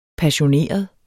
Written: passioneret

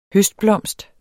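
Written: HØSTBLOMST

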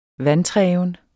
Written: vantreven

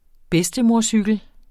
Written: bedstemorcykel

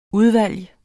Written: udvalg

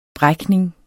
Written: brækning

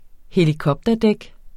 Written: helikopterdæk

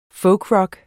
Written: folkrock

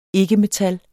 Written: ikke-metal

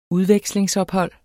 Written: udvekslingsophold

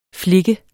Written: flik

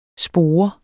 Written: spore

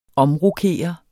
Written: omrokere

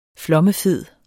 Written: FLOMMEFEDE

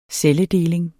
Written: celledeling

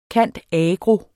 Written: cand.agro.

